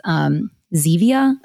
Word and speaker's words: Zevia: My husband actually has Zevia. That's how you say it. Like the stevia soda, because he's tried to wean himself off of it.